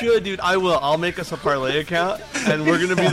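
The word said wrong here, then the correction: Parler: Yeah, parlor.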